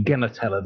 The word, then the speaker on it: Genertella: Hard G, Genertella.